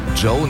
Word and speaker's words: Djo: Joe nennt er sich. Und es ist Joe Keery, spielt in Stranger Things. – Ja, und Joe schreibt man D-J-O.